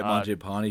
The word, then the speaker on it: Mangiapane: So I know that's not real specific, but I mean, Calgary, they still have Huberdeau, Hannafin, Mangiapane, however you pronounce it. – Yeah, Mangiapane.